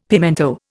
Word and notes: pimento: Indian

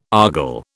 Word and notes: argol: British English

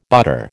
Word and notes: butter: American English

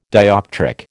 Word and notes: dioptric: American English

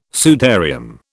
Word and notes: sudarium: British English

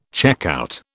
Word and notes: checkout: British English

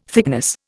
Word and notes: thickness: Indian

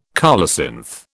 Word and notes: colocynth: British English

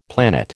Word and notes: planet: American English

